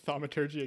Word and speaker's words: thaumaturgy: Thaumaturgy again. – Thaumaturgy again.